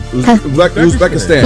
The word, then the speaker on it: Uzbekistan: How do you say this? – Uzbekistan. – Uzbekistan, just the way it looks? Uzbekistan? Yeah.